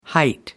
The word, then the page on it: height: American English